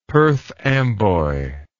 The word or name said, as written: Perth Amboy